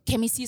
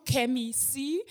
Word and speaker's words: Kemi Cee: Kemi C ist mein Künstlername und ähm, genau so wird es auch ausgesprochen, Kemi C.